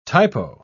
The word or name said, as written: typo